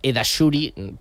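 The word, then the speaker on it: Eddahchouri: También tiene un apodo curioso, también, porque en Depor, o sea, en el Depor, en Coruña, claro, su nombre es complicado, Zakaria, bueno, yo digo es Edashuri, se escribe con varias Ds, SH,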